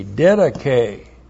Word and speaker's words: didache: D-I-D-A-C-H, long E. Didache.